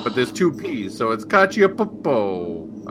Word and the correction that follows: Cacioppo: I don't know. – No, it's just Cacioppo. You don't pronounce the P's twice.